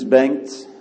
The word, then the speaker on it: Bengt: My name is Bengt. Don't try to pronounce it. It's a Swedish name.